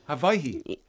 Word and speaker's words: Hawaii: Hawaii. That's how you say it, is it? – Hawaii. – Right. – Hawaii, yeah. – Right. – Okay. But Hawaii's fine.